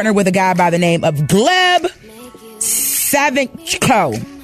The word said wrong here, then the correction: Savchenko: I think that ain't how you say it. No. You tried it. How you say it? – Gleb Savichko. – Nope. – Savchenko, I bet you when you watch the show, you're going to say Savchenko.